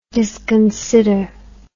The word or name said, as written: disconsider